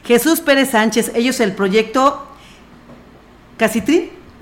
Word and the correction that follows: Catri: Catri. – Catri, perdón, perdón, discúlpame, por favor, Nidgar.